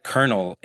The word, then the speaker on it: colonel: You know, a word like colonel in our language doesn't have an R in it, but we pronounce the R. So there are cases where you're pronouncing a letter that's not there.